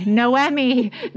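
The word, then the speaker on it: Noémie: We are pronouncing that right. We're reading it phonetically. Noemi. They wanted to make sure. It's N-O-E-M-I-E.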